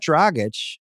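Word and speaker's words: Dragić: So for the listeners, they got Dragic in the Lowry trade. It's not nothing. – You nailed that pronunciation.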